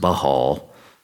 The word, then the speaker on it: Baal: It's a spin-off of the word Baal, sometimes pronounced Baal.